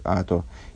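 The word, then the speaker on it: ато (אתה): Здесь в посуке встречается три раза слово «ато», «ты», Э, и первый и последний раз оно пишется как «ато», а средний раз, когда в отношении того, что ты сделал небеса и землю, э, оно пишется почему-то как, почему-то без «г» конечного. Э, несмотря на это, читается как «ато».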